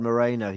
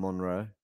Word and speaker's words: Moreno: Oh, like Gerard Moreno, he called him Monroe.